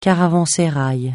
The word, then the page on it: caravansérail: francais